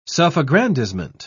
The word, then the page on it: self-aggrandizement: British English